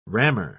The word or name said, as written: rammer